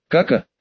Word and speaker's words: Кака: Помахав ручонками перед своим лицом, детеныш поднял на нас васильковые глазки и растерянно проговорил, делая ударение на последнем слоге. «Кака, господин!» — проревел дворецкий, роняя тарелку вместе с оставшимися кусочками молодильного яблочка.